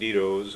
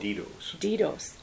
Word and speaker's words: Dedoes: So Jake Dito's, who's another friend of ours. – How do you spell that last name? – D-E-D-O-E-S. – Thank you. – Dito's.